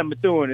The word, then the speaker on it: Methuen: And you're from, pronounced Methuen, Massachusetts? – Yeah, Methuen.